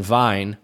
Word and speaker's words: Wein: You know, I had many German students who would pronounce the EI and IE's opposite, you know? Mm-hmm. So, so like, like Wien, which is German for Vienna, and then Wein, which is German for wine.